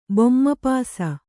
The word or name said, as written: ಬೊಮ್ಮಪಾಸ